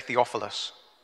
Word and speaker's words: Theophilus: Everybody say Theophilus.